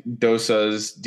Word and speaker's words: dosa: I think dosas, D-O-S-A, are still my favorite.